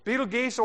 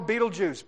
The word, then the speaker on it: Betelgeuse: Betelgeuse or Betelgeuse. Both pronunciations are correct. I like Betelgeuse because it just sounds like you stepped on one, and that's what he is.